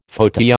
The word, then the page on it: fauteuil: American English